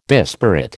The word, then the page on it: disparate: American English